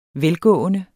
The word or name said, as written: velgående